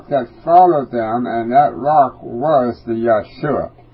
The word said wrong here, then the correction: Yahushua: I say Yahshua, it's Yahushua. Y-A-H-U-S-H-U-A. I mispronounced that U in the middle.